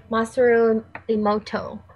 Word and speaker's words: Masaru Emoto: And I really encourage you to look up the work of Masaru Emoto. And his name is spelled M-A-S-A-R-U. And his last name is E-M-O-T-O.